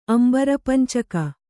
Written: ಅಂಬರಪಂಚಕ

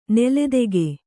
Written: ನೂಲ್ದೆಗೆ

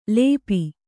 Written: ಲೇಪು

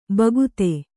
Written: ಭಕ್ತ

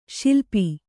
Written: ಸಲಪು

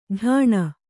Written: ಢಾಣ

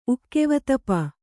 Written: ಉಕ್ಕೆವತಪ

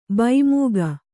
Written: ಭೈಮೂಗು

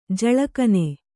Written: ಜಳಕನೆ

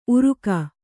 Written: ಉರುಗು

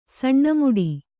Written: ಸಣ್ಣಮುಡಿ

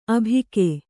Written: ಆಭೋಗ

